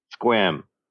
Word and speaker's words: Sequim: And so it was the city of Squim, which is pronounced S-E-Q-U-I-M. And like one of the things on it, because back then the net was very multimedia. It was all about, you know, we can embed sounds or whatever. And so you'd click on their homepage, and it just was like, you know, City of Squim, Washington. And then underneath it's just a sound file. And you click on it, and the sound file is this guy in this incredibly deadpan voice saying, Squim, it's pronounced Squim.